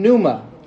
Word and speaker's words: pneuma: And it's made up of 2 Greek words, theos, which from which we derive God, and then pneuma, as silent P on there, which is translated as wind or breath or spirit within the appropriate context.